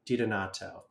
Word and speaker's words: DiDonato: DiDonato, thank you.